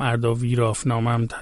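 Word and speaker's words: ارداویرافنامه: خب حالا که اینقدر از کتاب اردویرازنامه گفتیم، که گفتم اردویرافنامم تلفظ میشه،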